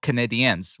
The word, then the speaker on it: Canadiens: For example, Stephanie Brown wrote, "If Liel wants a team to root for, I strongly suggest the Montreal Canadiens, which she wrote rhymes with comedian.